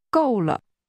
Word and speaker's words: 够了: 够了. – This is pronounced with two falling tones.